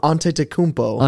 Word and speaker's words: Antetokounmpo: Uh, Antetokounmpo.